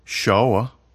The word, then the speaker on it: sure: Many, actually, but one is the word sure, S-U-R-E. It can be pronounced sure in New York. Are you sure? Or shawa in the South. Are you shawa? Or in a Scottish neighborhood, are you sure, laddie?